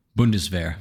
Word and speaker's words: Bundeswehr: Bundeswehr.